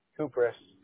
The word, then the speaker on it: Kupris: Okay, so G-A-B-R-E-I-L-A-L, or how do you spell it? – No, G-A-B-R-I-E-L. – I-E-L. And last name, is it Krups? – Kupris. – Kupris. I'm just blowing it today, huh?